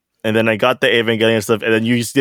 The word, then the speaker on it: Evangelion: So JP picked up a Evangelion.